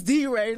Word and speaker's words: DeRay: I must have said DeRay, and he was like, it's D-Ray.